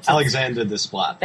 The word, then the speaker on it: Alexandre Desplat: Rahman, How to Train Your Dragon, John Powell, Inception, Hans Zimmer, The King's Speech, Alexandre Desplat. – Alexander Desplat. – Thank you. I've, like, butchered someone's name.